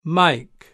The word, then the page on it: Mike: British English